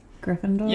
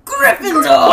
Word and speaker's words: Gryffindor: Gryffindor? – Yeah, that's fine. Yeah. I'm taking the Potter. – Wait, first. Sorry, I said it wrong. Gryffindor!